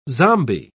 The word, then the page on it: zombi: British English